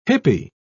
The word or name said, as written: hippy